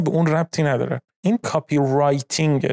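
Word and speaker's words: copywriting: که توی فارسی بعضی وقتا اونو کپی رایتینگ هم میگن، اما تلفظ درست کلمش میشه کاپی رایتینگ.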